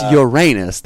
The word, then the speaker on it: Uranus: But, it's Uranus. That is the pronunciation. That's final. Yes.